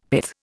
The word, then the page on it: pith: Indian